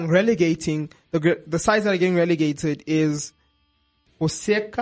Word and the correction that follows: Huesca: I think that's how you say it. – Huesca, Villarreal, and Eibar.